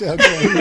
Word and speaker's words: Joaquin: It's not Joaquin. What is it? – No, it's Joaquin. – Oh, is it? – It's Joaquin.